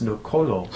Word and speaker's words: Noxolo: And I've just found out now how to pronounce her name. It's Nokolo. It's spelled with an X.